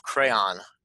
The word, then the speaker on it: Crayon: Hey guys, Chief Revenue Officer of Crayon. Am I pronouncing it right? – Yes, you are.